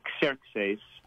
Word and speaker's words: Xerxes: In the Greek, it would be Xerxes.